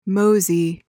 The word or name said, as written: mosey